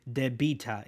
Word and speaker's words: debita: Debts is, you know, when we say when we spell the word debt, there's a silent B, but in Latin we pronounce everything, so it would be debita instead of just debt.